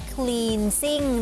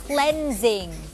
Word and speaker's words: cleansing: ไม่ได้อ่านออกเสียงว่า cleansing นะคะแต่ว่าอ่านออกเสียงว่า cleansing คำนี้มาจากคำว่า cleanse